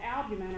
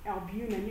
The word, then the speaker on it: albumin: So that first one there called albumin or albumin, you can pronounce it either way, really important, yeah, for maintaining blood pressure.